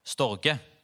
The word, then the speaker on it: Storge: Første er, og jeg kan ikke uttale disse, så eh, du får ha meg unnskyldt, men den første uttaler vi på norsk, så heter det stor G. Storge.